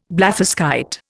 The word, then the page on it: blatherskite: Indian